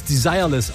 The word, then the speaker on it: Desireless: Besser bekannt als Desireless, die aber meistens als Desireless ausgesprochen wird.